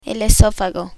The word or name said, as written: esofago